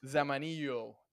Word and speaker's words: Zamanillo: Zamanillo. – Thank you. I was going to say the American way and completely butcher your last name.